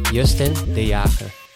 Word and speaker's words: Justin de Jager: Give us the proper like Dutch pronunciation. – Yeah, so my name is Justin de Jager.